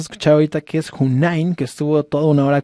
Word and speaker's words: Hoonine: Vayan y búsquenla, se escribe H-O-O-N-I-N-E, Hunain, así la pueden buscar en todas las redes.